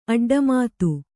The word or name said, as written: ಅಡ್ಡಮಾತು